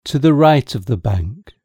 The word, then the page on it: to the right of the bank: British English